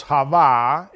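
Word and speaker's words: Hava (חוה): Because Hava is the way in which you say in Hebrew Eve. Eva is how you say it in Spanish. But in Hebrew, if you want to refer to that name, is of course Hava.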